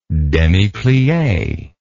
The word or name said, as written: demiplie